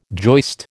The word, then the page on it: joist: American English